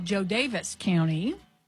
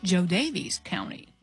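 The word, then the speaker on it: Jo Daviess: We have a lot of listeners who are from, I'll say it the way I pronounce it now, Joe Davis County. – Oh. – But we know there are some people that call it Joe Davies County.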